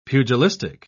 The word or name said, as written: pugilistic